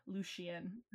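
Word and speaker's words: Lucien: But I didn't say Lucian, right? I said Lucian. – Oh, yeah, with the sh, Lucian.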